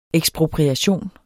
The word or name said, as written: ekspropriation